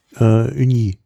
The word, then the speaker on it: Ugni: Aus der Region Gaillac und die Sorte Uni Blanc. Oh, also UGNI geschrieben, ich will es jetzt hier nicht komplett falsch, wie spricht man das? Wenn das UG? – UGNI? – Ja, Uni.